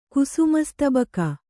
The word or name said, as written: ಕುಸುಮಸ್ತಬಕ